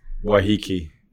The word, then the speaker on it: Waiheke: It's Waiheke, that's the island across. – Waiheke.